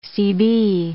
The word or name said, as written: CB